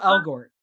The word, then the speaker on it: Elgort: Ansel Elgort is, how do you say his name? Elgort? – Ansel Elgort. Elgort.